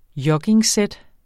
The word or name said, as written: joggingsæt